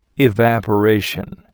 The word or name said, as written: evaporation